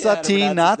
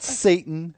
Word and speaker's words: Satin: Satin, not Satan. It's okay. Josh Satin, like the fabric, who was just called up by the Mets and got his first major league hit today on Sunday.